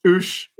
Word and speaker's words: Ouche: Ouche. Which is spelt like ouch. The river ouch.